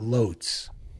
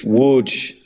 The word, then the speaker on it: Łódź: It's spelled with an L, but you keep telling me it's not Lotz. – It's Łódź. It's an L with a slash through it, which is more like a W, but it's Łódź.